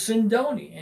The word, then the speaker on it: Sindoni: His name was, and my name is Sindoni, and it rhymes with Ronzoni, so Ronzoni sono buoni.